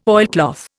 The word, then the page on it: oilcloth: Indian